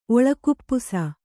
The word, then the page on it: ಒಳಕುಪ್ಪುಸ: kannada